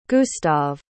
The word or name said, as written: gustav